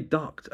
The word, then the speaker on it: Dubke: I say Duct. It's spelled D-U-B-K-E.